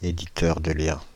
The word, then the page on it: éditeur de liens: France (Île-de-France); /e.di.tœʁ də ljɛ̃/